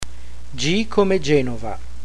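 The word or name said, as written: G come Genova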